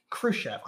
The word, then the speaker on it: Khrushchev: He had the respect of Premier Nikita Khrushchev. – Khrushchev. – I knew I was going to get that right. – Yeah, you were saying it right.